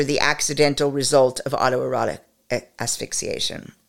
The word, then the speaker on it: asphyxiation: Autoerotic I always do this, so I'm not even going to correct this error or edit it out because so many of us pronounce asphyxiation poorly. So there it is. I've said it correctly now. It's asphyxiation.